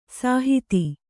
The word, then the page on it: ಸುಹಿತ: suhita